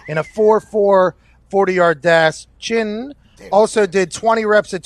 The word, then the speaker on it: Chinn: It's Chinn, right? Or is it Chinn? – It's Chinn. Chinn. – Chinn. The second N is silent. – Second. Silent, or you could just hold it in a little bit longer.